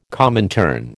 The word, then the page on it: Comintern: American English